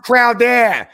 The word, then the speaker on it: Crowder: It's pronounced Crowder. Say it right, Frenchie.